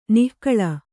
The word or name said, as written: ನಾಗುಳ